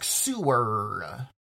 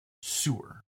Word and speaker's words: Soor: It is not S-E-W-E-R. They're not like, you know, going beneath the city to do this. This is S-O-O-R. – Yep. – Which we think is pronounced Suhr.